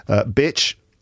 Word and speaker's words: bitch: Bitch, the short I sound, eh, bitch. And then beach, that long E sound, E, beach.